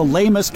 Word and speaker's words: Leymus: So again, this is the Lamus triticoides lagunita. Lamus is spelled L-E-Y-M-U-S.